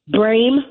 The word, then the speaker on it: Brame: Okay, it's WinRed, WINRED, Brame, my last name, BRAME, for Congress.